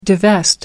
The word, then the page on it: divest: American English